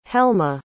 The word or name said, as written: helma